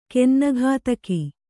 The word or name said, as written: ಗನ್ನಗತಕ